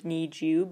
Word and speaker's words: NiziU: They're called Niziu because it's like supposed to sound like Niziu, but it's spelled N-I-Z-I-U.